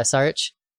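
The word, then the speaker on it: Šarić: Dario Saric? – Yeah, Saric.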